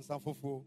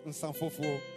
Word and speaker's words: Nsanfufu: How do we pronounce it? Nsanfufu. Ah, okay. Nsanfufu. Nsanfufu. – Nsanfufu. – Those who drink it, they know how to pronounce it.